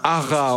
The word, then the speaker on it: haras: Oui, un hara, on prononce comme ça. H-A-R-A-S. Un hara.